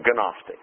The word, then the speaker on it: Gnostic: And that's spelled with a G, by the way, Gnostic, if you want to look it up, the Gnostic error.